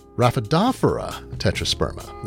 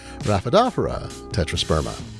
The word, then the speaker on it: Raphidophora tetrasperma: My wife, as noted, a horticulturist, told me it's actually pronounced Raphidophora tetrasperma. Now, I pronounced it a different way. I'm not going to perpetuate that, so say it with me, Raphidophora tetrasperma.